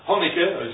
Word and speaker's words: Hanukkah: Hanukkah is one of them as well. That's how you pronounce it, isn't it, Gene? – Yeah.